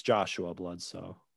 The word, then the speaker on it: Bledsoe: Oh, yeah, it's Joshua Bledsoe.